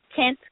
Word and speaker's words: Kent: When you type in my name is spelled Krush with a K, K-R-U-S-H. My last name is Kent, K-E-N-T.